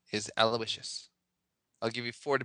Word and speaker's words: Aloysius: A-L-O-Y-S-I-U-S. Aloysius?